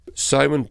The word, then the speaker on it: Samhain: Samhain, by the way, for those listening, is spelt S-A-M-H-A-I-N.